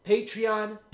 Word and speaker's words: Patreon: Is that how you say it? Patreon? All right, good.